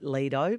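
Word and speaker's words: Lidö: But they got married on an island quite far north on the archipelago called, pronounced Lida, but like Lido, think Boz Skaggs.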